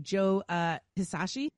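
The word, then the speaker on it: Joe Hisaishi: Joe Hisashi, is that right? – Yeah.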